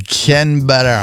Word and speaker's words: Canberra: Canberra.